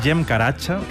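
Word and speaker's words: Cem Karaca: Este es Jem Karacha, que se escribe Zem Caracas, si lo queréis buscar.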